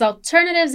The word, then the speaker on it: alternatives: In American English, we have that R in the T, right? Alternatives. That R sound.